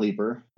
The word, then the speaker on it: Leeper: Yeah, so they can find me on Facebook, Scott Lieber. I can't jump, so it's spelled L-E-E-P-E-R.